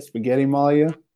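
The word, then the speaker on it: Spagettimalja: Is that how you say it? Spaghetti Malia? – Yeah, that's right.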